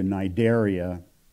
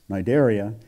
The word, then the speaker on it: Cnidaria: It was changed to Cnidaria, meaning Cnid, C-N-I-D, the C is silent, referring to, I think the origin of the word is the nettle, the plant, the nettle. So that's Cnidaria.